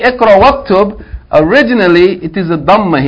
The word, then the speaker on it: waktub: What if you had ikra, read, and write? How would you pronounce this? This is hamzatul wasl. How would you pronounce it? Waktub. Iqra, waktub. Why is it waktub? Because this hamza tawassul. You have to join whatever is before to whatever is after it.